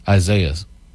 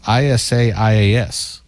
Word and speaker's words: Isaias: The hurricane is Isaiah's. It's not pronounced that way. It's Isaias. There's no H in it.